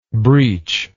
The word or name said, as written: breacher